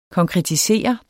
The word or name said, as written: konkretiseres